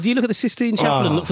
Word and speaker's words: Sistine Chapel: I think you'll find it's pronounced the Sixtine Chapel. You've embarrassed yourself there. Yeah. – Is it really? – No, of course it isn't.